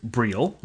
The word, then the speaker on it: Briel: So, as promised, I wrote to Vince Briel, and I learned, well, first, that it is pronounced Briel, like eel, and I also learned that this ROM business turns out to be a very interesting topic.